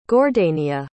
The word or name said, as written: gordania